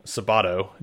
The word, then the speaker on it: Sabato: I'm joined by a special person today, Antonio Sabato.